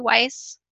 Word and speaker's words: Weiss: Am I saying it correctly, Weiss? – You are. Why? – Okay. – Like smart. – Oh, smart, wise.